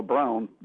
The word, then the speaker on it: Braun: Braun did, it's Braun, not Braun, it's pronounced Braun in Germany, they did not kill themselves in the bunker.